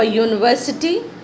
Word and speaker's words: university: For university we pronounce as yo, y double o. This is a consonant sound.